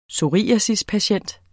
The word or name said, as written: psoriasispatient